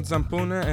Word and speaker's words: Zampone: Yeah, my name is Stefano Zampone, and I come from Caserta, Italy.